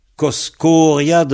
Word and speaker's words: Koskoriad: C'est Koskoriad Neol. Alors, Koskoriad, ça s'écrit K-O-S, K-O-R-I-A-D. Kos, K-O-S, Kor, K-O-R, I-A-D, I-A-D, Koskoriad, Koskoriad, an Heol, H majuscule E-O-L, Koskoriad an Heol.